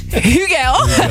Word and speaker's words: Hugel: Dan wou ik weer Miguel zeggen. Miguel met een H. Hugel.